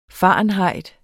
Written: fahrenheit

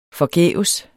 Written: forgæves

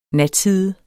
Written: natside

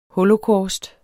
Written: holocaust

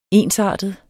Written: ensartet

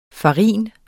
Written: farin

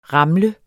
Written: ramle

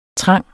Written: trang

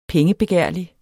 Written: pengebegærlig

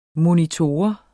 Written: MONITORER

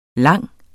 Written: lang

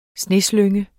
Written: sneslynge

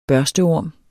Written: børsteorm

